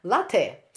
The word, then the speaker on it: LATE: Организация, название организации пишется по-английски как L-A-T-E, латте или Late? Как правильно? – Э, я очень рада вас принять у себя дома. Э, наша организация называется «Латте». – Э, «Латте» – это лоскуток.